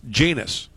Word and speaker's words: Llenas: Their manager was a guy, Winston, and his last name was L-L-E-N-A-S. So the Angels media guide said it was pronounced Janus, which I assumed was correct.